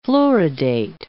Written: fluoridate

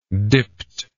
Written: dipt

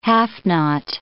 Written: half-knot

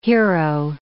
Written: hero

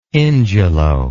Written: ingelow

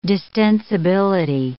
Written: distensibility

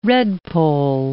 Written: redpoll

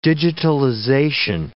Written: digitalization

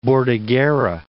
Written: Bordighera

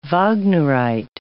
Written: Wagnerite

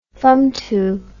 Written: fumtu

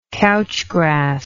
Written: couch grass

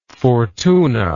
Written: Fortuna

